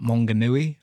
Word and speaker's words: Maunganui: And there was a place we went to last time called Mount Maunganui.